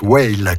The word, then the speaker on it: WAIL: Les quatre lettres de son indicatif sont W-A-I-L et ça se prononce Whale, comme une baleine.